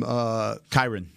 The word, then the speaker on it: Kyren: Is it Kyren Williams from Kyren.